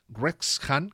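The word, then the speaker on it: Wrexham: a ver si lo pronuncio bien, Rex Han, que se escribe con W al principio, mm.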